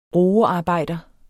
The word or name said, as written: roearbejder